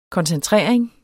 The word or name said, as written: koncentrering